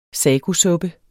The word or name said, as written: sagosuppe